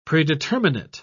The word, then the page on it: predeterminate: British English